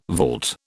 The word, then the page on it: vault: British English